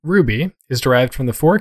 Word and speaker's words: RWBY: I'm a little light this week, but straight from Wikipedia, in case you're listening to this episode and have no idea what Ruby, spelled R-W-B-Y and pronounced Ruby, is about.